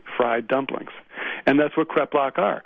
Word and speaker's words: kreplach: This photo of kreplach, is it kreplach, is that how you pronounce it? – That's correct.